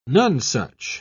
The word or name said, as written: nonesuch